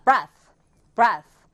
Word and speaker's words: breath: breath, 얘는 끝에가 th, 혀를 살짝 물어주는 th 사운드예요. 근데 breathe라고 이가 붙게 되면 이 모음과 함께 쓰여서 th가 d가 됩니다. 그래서 breathe가 되는 거예요.